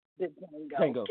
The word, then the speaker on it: Django: The D is silent. Django.